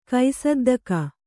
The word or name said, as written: ಕೈಸದ್ದಕ